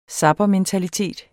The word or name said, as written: zappermentalitet